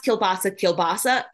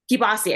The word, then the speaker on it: kielbasa: And I was pronounced kielbasa, but as I was doing more research about the kielbasa cheesesteaks, I realized that other people pronounced it, oh my gosh, a different way. Kibasi, I think it's kibasi is another way to pronounce it.